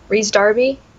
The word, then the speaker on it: Rhys Darby: Oh, I sometimes get his name wrong. I hope I'm right here. Rhys Darby? – Yes. – I think it's how I... Okay, good. Because I used to say it wrong. – It's either Reese or Rice. – Yeah, somewhere there. I hope I'm saying it right.